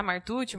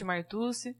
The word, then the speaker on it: Martucci: É isso mesmo, apesar das complicações para se pronunciar Martucci, Martucci, para facilitar um pouquinho aí.